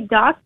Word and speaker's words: Dost: There's really no guide on how to pronounce it. I usually just say Dost because I'm not Spanish.